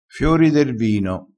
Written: fiori

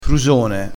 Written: frusone

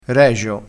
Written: regio

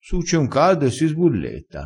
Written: sbulletta

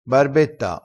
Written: barbetta

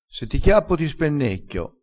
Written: spennecchia

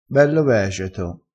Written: vegeto

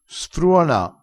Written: sfruona

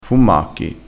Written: fummacchi

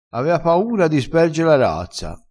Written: sperge

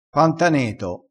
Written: pantaneto

